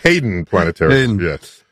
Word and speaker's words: Hayden: Do you say Hayden or Haydn? – Hayden. Haydn is the composer.